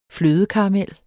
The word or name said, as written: flødekaramel